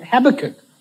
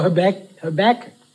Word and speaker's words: Habakkuk: There's one of my favorite prayers in the Bible is in an Old Testament book called Habakkuk or Habakkuk, chapter 3. Nobody knows how to say it, so I've got to say it in the American and in the South African way.